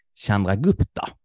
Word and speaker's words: Chandragupta: Utan det börjar som ett litet, litet land eh, som regerades av kung Chandragupta. Exakt så det uttalas.